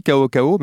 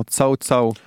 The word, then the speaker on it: Cao Cao: Je crois que effectivement, ça se lit Kao Kao, mais en fait, ça se prononce genre Tsao Tsao.